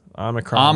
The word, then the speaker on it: Omicron: Speaking of mitigating the bad, Omicron. I say that right? – Yes, Omicron.